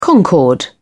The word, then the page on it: concord: British English